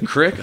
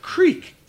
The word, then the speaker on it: creek: And they'd be like, the Crick. I'd be like, Creek. Two E's.